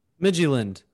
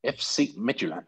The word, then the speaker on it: Midtjylland: Midtjylland. I'm glad you said that out loud. One of the first times I've heard it said out loud. Now I know how to say it.